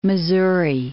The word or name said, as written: Missouri